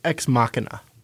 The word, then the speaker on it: Ex Machina: And then there was, I pronounced it Ex Machina, but you said Ex Machina. – Yes, I believe it's Ex Machina.